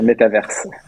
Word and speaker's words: métavers: Voilà pourquoi on prononce pas le S à la fin. Voilà, je fais mon intéressant. En français. Hein. en français, oui, bien évidemment. – Il faut dire métaverse.